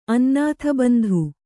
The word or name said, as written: ಅನ್ನಾಥಬಂಧು